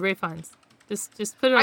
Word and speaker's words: Ralph Fiennes: It sounds like she's saying Ralph Fiennes, but it says... The spelling of how you should pronounce it is Rafe Fiennes.